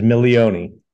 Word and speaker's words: Milioni: Yeah, my last name is Milioni.